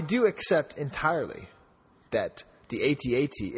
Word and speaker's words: AT-AT: And correct me if I'm wrong, but did the Haunted Mansion-sounding voiceover guy pronounce it AT-AT? – Yes, and you know why? Because that is actually the British pronunciation.